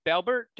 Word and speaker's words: Balbert: Like the name of your marketing business is Balbert. Did I say that correctly? – Yeah. Balbert Marketing. That simple.